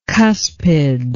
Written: cuspid

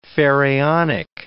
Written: pharaonic

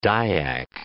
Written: Dyak

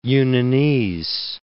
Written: Yunnanese

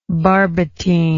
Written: barbotine

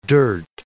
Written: dirt

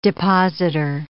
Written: depositor

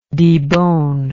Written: deboner

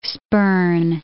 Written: spurn

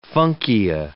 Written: funkia

